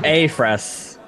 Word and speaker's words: Afress: Afris. – Afris. – So, yes, it's pronounced as a soft A.